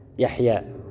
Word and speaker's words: يحيى: حذف الياء يحيى، يحيى، لاحظوا يحيى الياء محذوفة لكن احنا ايش؟ ننطقها يحيى.